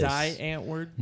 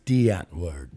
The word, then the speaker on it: Die Antwoord: Die Antwoord? – No, it's pronounced D Antwoord.